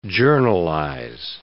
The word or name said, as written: journalize